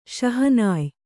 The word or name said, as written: ಶಹನಾಯ್